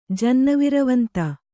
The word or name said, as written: ಜನ್ನವಿರವಂತ